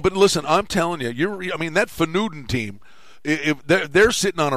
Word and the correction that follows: Feyenoord: How do you say it? – It's Feyenoord. – Yeah, them. Those guys. – Feyenoord.